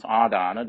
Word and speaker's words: Adana: Adana, that is A long A, DA long A, NA Adana. So Adana, this is how you form the word Adana. A long A plus Dana.